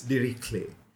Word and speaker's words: Dirichlet: It's Dirichlet.